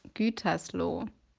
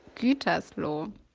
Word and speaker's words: Gütersloh: Hi, your pronunciation is really shitty. So it's called Gütersloh. Okay, again, Gütersloh.